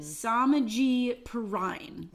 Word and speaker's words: Samaje Perine: For me, it's Joe Mixon cuffed to, what's his name? Sajeen Perine? – Okay, so I wrote this down to make sure. – Samaje Perine. – Yeah. – Perine. – I'm sorry. – You put it down phonetically?